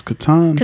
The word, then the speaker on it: Catan: It's Catan.